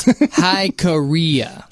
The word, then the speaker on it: Jicarilla: It's Hikaria. That's the name of Hikaria. – Hikaria. That's Hikaria. The name of that mountain.